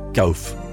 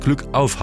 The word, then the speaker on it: Glück auf: Wenn ich kam, sagten sie Gauf. Bis ich irgendwann mitbekam, dass es Glück auf heißt. Diesen Gruß kannte ich natürlich, nur die Aussprache war mir völlig neu.